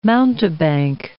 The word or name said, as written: mountebank